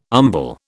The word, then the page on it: umbel: British English